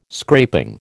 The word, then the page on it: scraping: American English